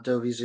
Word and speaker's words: Dovi: Am I pronouncing your first name correct? – Yeah, you're pronouncing it right. Like, you know, I, I go by Andy sometimes, you know, uh, Dovi's,